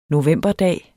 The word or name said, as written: novemberdag